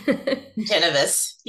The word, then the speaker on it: Ingenovis: Ingenovis.